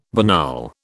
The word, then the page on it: banal: British English